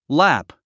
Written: lap

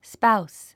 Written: spouse

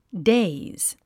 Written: daze

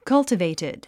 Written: cultivated

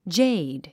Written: jade

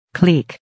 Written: clique

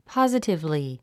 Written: positively

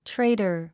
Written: traitor

